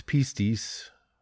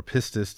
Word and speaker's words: pistis: One is the noun version that's very frequently translated as faith, and it's pistis or pistis, depending on how you would pronounce the I. We would spell it P-I-S-T-I-S.